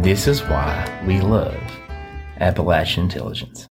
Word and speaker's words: Appalachian: By the way, when I said Appalachian Intelligence when we recorded with the Cryptids of the Corn, somebody left a comment on YouTube that said, say it right. – He did say it right. Whoever that person on YouTube is, he is saying it the right way.